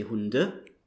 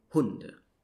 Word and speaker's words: Hunde: So in German, usually you stress the first syllable in the words. So you wouldn't say Hunde, but you would say Hunde.